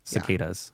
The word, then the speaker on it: cicadas: Yeah, and the online pronunciation I'm finding is cicadas. Cicadas. Yeah. Like D-U-H-Z, does cicadas.